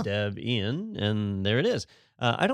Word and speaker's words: Debian: This is, 30 years of, is it Debian? Is that how you say it? – It's Debian. – Debian. Okay, yep. That is the correct way. – It is, yes.